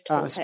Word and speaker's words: Toltec: Toltec? – Yes, it is Toltec.